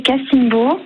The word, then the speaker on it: Cassimbo: C'est bien comme ça qu'on prononce ? – Exactement, c'est Cassimbo.